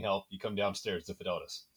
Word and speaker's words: Fidelitas: I'm going to have to get you to pronounce the name here. – Fidelitas. – Fidelitas. Yes, perfect.